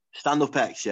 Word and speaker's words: StandUp X: Am I saying it right? Stand X? – Stand Up X, yeah.